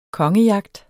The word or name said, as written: kongejagt